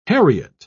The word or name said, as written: heriot